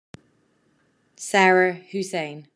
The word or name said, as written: Sarah Taimoon Zaida Hosein